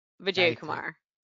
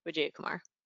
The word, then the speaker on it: Vijayakumar: So it's Gayathri Vijayakumar.